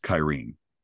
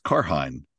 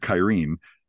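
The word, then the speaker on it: Cairhien: was Kyrene, or if you read the books, Karhain. So I did not know that you pronounced it Kyrene, but it was towards the end of this episode, and it was when they were going.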